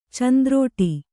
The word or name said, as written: ಚಂದ್ರುಟಿ